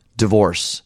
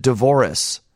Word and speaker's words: divorce: Divorce. It's two syllables. Until about 2 years ago, I said Dvoris. – What? – Like, that rhymed with Dolores.